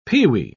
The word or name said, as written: pewee